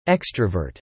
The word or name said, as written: extrovert